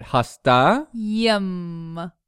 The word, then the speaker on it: hastayım: Right, hasta. – Yım. – Right, so hasta is sick, that im, I am. Hastayım. – Hastayım. – With a buffer Y.